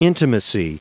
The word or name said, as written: intimacy